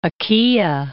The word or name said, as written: Achaea